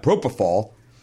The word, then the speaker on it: propofol: They put me on that propofol.